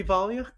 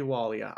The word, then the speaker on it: Wallia: And therefore, it could be Valia, could be Wallia. I don't know. – Pick your favorite, because unfortunately, books don't always tell us how to pronounce things.